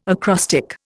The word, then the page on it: acrostic: Indian